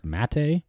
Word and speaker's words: mate: And yeah, then every adventure started with, I believe it's pronounced mate, M-A-T-E, that like tea beverage that they drink in the morning, like religiously through the whole adventure.